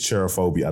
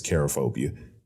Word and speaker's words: cherophobia: I think it's cherophobia. I don't think it's charophobia. Cherophobia.